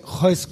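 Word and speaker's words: Royce: Heus Gracie Heus, war das, genau. genau. Alle R's immer wie H aussprechen, genau.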